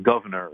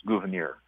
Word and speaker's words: Gouverneur: I pronounce it Gouverneur, but others pronounce it Gouverneur. If you look at the Adams correspondence between Abigail Adams and John Adams, she spells out how to pronounce it, and it looks like it's Gouverneur, but his family was French or French Huguenot, so maybe if you say it with a French accent, but it's not Governor, it's Gouverneur.